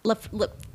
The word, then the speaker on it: Laphroaig: Oh, well, I bought them Laphroaig. Am I saying it correctly? Laphroaig, scotch. – Oh, Laphroaig. – Laphroaig. – Laphroaig. – Oh, wow. – Yes. – I was saying it really Laphroaig. That sounds way more Scottish, though, yeah.